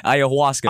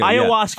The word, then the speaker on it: Ayahuasca: Ayahuasca. – Ayahuasca.